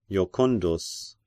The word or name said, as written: Jocundus